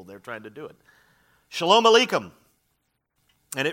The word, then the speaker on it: Shalom Aleichem: In Hebrew, it is pronounced Shalom Aleichem. Can you say it? Yeah, very good. Shalom Aleichem.